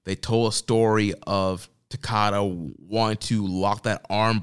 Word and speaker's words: Takada: So I did watch the Battle Formation matchup there with Hashimoto going for the IWGP title against, was it Takeda? Is that how you say his name? – Nobuhiko Takada. – Takada. Okay, so yeah, I did watch that match, and it was a very good match.